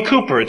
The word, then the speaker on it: Cowper: William Cowper, it's spelled William Cowper, but the great hymn writer of London, England, William Cowper, a real contemporary, actually, of John Bunyan, the writer of Pilgrim's Progress, and they were great friends.